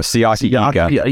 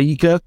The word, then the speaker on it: Siaki Ika: And um, so the next pick how do you say the guy's name again? This is really terrible of me, but Siaki Ika. Ika. Ika. Yeah. Okay.